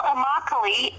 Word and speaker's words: Immokalee: Well, Immokalee is a town in Florida, and for those who would like a hint on pronouncing it, it rhymes with broccoli.